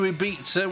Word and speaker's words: Ibiza: And before that, we were with the Venga Boys, who were off to Ibiza, which is the Dutch pronunciation of Ibiza.